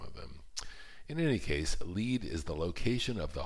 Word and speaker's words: Lead: I did make a point of stopping in Lead, South Dakota, that's L-E-A-D, but pronounced Lead, and so named because it was here, around 1875, that prospectors found the first lead to a vein of gold ore.